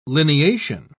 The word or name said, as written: lineation